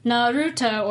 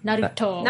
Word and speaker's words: Naruto: It's the middle syllable, so Naruto or Naruto.